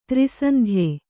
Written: ತ್ರೈಸಂಧ್ಯೆ

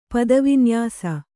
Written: ಪದವಿನ್ಯಾಸ

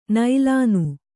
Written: ನೀಲಿನಿ

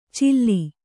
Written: ಚಿಲ್ಲಿ

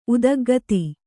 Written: ಉದಗ್ಗತಿ

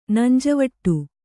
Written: ನಂಜವಟ್ಟು

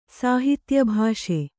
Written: ಸಾಹಿತ್ಯಭಾಷೆ

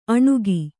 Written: ಅಣುಗಿ

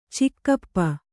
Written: ಚಿಗಪ್ಪ

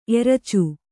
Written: ಎರಚು

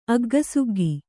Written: ಅಗ್ಗಸುಗ್ಗಿ

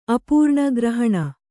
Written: ಅಪರ್ಣ

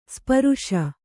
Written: ಸ್ಪರುಷ

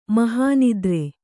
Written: ಮೋಹನಿದ್ರೆ

